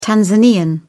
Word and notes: tanzanian: British English

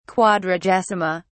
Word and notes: quadragesima: British English

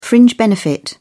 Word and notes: fringe benefit: British English

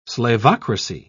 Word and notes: slavocracy: British English

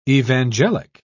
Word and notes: evangelic: British English

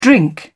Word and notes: drink: British English

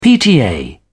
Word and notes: pta: British English

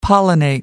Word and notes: pollinate: British English

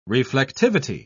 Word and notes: reflectivity: British English

